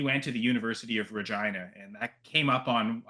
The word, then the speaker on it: Regina: Make sure Regina.